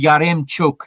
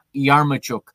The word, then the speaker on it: Yaremchuk: It's Yaremchuk, not Yarmuchuk.